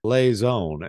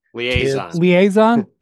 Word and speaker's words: liaison: He is the perfect liaison, and I think I'm pronouncing that word correctly, the perfect liaison in the locker room between Liaison. – I was close.